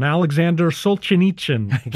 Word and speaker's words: Solzhenitsyn: Alexander Solzhenitsyn, right? I think I said it right. – Yeah, no, yeah.